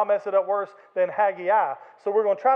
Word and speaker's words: Haggai: If you want to hear the literal Hebrew pronunciation is Haggai.